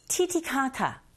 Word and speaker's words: Titicaca: Lake Titicaca, T에서도 T를 TT 하셔도 되고 TD 하셔도 됩니다. 아셨죠? Google listening 1. The Lake Titicaca joined to Puno. Alright, Google listening 2. The Lake Titicaca adjoined to Puno.